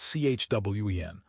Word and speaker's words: Qwen: Open-weight models like Kimi K3, pronounced Kimi K3, and QWEN, pronounced CHWEN, are catching up to frontier models.